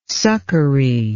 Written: succory

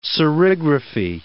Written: serigraphy

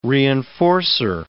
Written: reinforcer

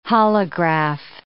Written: holograph

